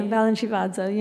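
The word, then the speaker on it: Balanchivadze: and who this little Georgi Balinshevats, is that how you say it? – Yeah, Balinshevadze, yeah.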